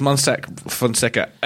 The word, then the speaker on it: Mossack Fonseca: Pronounced Mossack, as you see it. – I think so. Okay. Somewhere out there in Panama, there might be someone going, Monseca, you idiot. But like, it's Mossack Fonseca.